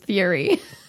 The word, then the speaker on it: Fury: Spell it. – No, it is. It's Fury.